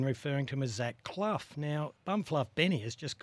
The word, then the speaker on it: Clough: So it's definitely Clough, C L O U G H. – Yeah, Clough. Yeah, Love it's hard it. to uh, pronounce it for Aussies, I think.